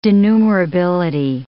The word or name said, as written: denumerability